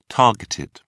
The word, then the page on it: targeted: British English